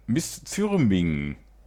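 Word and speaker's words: Misþyrming: Black Metal aus Island von Miss Thürming. Ich hoffe, ich habe es richtig ausgesprochen. Es ist dieses isländische P, das man aber wie ein englisches TH ausspricht.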